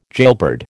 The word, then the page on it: jailbird: American English